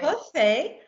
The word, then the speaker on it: Hefei: From Hefei. Hefei. – Say it again. – He. Yeah, Fei. – Hefei. – Yeah, it's great. – Hefei.